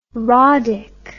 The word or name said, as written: roddick